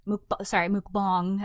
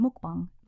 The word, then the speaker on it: mukbang: Mukbang. That's how you say it. Mukbang.